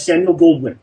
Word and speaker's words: Samuel Goldwyn: But the thing is that the day after that, Samuel Goldwyn or whatever, am I pronouncing that wrong, but the G of MGM. – Yeah, that's Samuel Goldwyn.